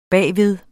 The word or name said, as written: bagved